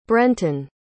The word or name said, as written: brenton